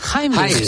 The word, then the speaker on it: Heimlich: La siguiente fase sería la famosa maniobra de Heimlich. Creo que se pronuncia, alemán. – Heimlich, sí.